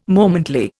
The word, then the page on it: momently: Indian